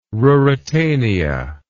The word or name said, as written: ruritania